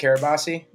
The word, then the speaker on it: Carabase: Carabase? – Nah, dude, you had it.